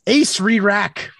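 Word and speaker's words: Acererak: Now it has Alurin and, forgive me if I pronounce this, Acerarak. – Acerarak, the Archlich. – No, there's an extra ER in there for some reason. – Ace Re-Rack.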